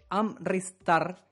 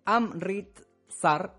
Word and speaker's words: Amritsar: Así es, porque en la ciudad de Amritsar... No, perdón, lo pronuncié mal de nuevo. En la ciudad de Amritsar, ahí sí,